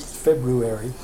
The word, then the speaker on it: February: February. – That's wrong. – Oh, is it? – I don't know. The teachers always told us to leave the R out. It was a silent R.